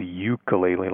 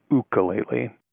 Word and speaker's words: ukulele: I think the most common pronunciation is ukulele, like Y-O-U, but it's actually ukulele.